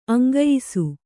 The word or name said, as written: ಅಂಗಯಿಸು